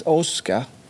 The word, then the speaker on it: aska: Och aska, det uttalades åska i Huaröd.